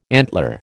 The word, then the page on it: antler: American English